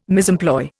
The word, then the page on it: misemploy: Indian